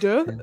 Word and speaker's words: deux: Is it deux? – Part deux? – Yeah.